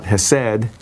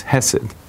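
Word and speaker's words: Hesed (חסד): The emphasis is on the first symbol, a syllable. It's not Hesed, it's Hesed, because of the way the vowels work.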